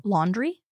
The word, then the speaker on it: Laundrie: Laundrie? – Laundrie. – Spell it. – L-A-U-N-D-R-I-E. – Yeah, Laundrie. Okay.